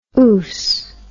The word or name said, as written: oose